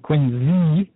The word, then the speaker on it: Quincy: all the common people we know out there in Quincy, as we call it, Quincy, even though it's spelled with a C.